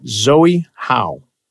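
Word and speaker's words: Chloé Zhao: The Z is silent, my friend said. So we'll call it Zoe Howe for Nomadland.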